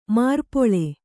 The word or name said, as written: ಮಾರ್ಪೊಳೆ